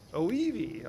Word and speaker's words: Oiwi: O-I-W-I. How do you pronounce that? Oivi, okay, I should have asked you that first.